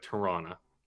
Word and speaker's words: Toronto: But Toronto, well, you don't pronounce the other T, it's like Toronto.